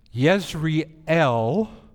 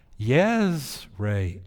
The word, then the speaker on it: Jezreel: Pronounced in Hebrew, listen to these two words. Yes, Jezreel, and this word, Israel. Jezreel, Israel.